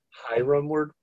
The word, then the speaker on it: Harem: I pronounce it Hiram Woodworking, but... – Ah, going the Japanese route as opposed to the Hebrew English route.